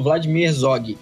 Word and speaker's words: Vladimir Herzog: Como é que pronuncia? – Isso, é isso mesmo. É Instituto Vladimir Herzog.